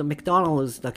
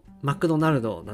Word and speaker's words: マクドナルド: 多分、英語で、英語だと発音するとメクドナルドだけど、日本語だとマクドナルドなんで、